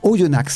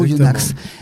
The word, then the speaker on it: Oyonnax: Et il vient en fait d'une ville, alors je ne connaissais pas, Oyonnax, c'est ça? On le prononce comme ça? – Oui, exactement. – Oyonnax.